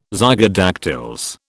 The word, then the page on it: zygodactyls: British English